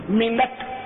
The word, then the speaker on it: منٹ: آغاز جماعت اصل سے 26 منٹ قبل, ہمارے منٹ بولتے ہیں 3 منٹ 5 منٹ, یہ غلط تلفظ ہے, منٹ نہیں منٹ, نون پر زبر پڑا کر.